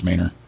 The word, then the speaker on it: Manor: M-A-N-O-R. It's actually pronounced Manor. It freaks me out every time I say it, but whatever. It's Manor.